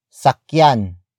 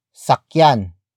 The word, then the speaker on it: sakyan: Sakyan mo ang kabayo. Sakyan mo ang kabayo. The first word is sakyan. Sakyan. Sakyan. Sakyan is spelled as S-A-K-Y-A-N.